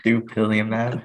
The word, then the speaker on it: Dupilumab: Dupilumab.